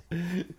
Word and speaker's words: Tenet: It's pronounced Tenet. Tenet. It's a real word. – I know. It's the it's the person who rents from a landlord. – No. – No. Oh, that's no. That's tenant.